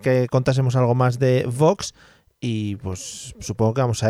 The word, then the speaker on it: Vox: Oye, que bien has pronunciado Vox, ¿no? Sí, porque estoy intentando traer otra vez las, las dobles y la buena pronunciación de la W al castellano. Se está perdiendo.